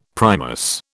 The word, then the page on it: Primus: British English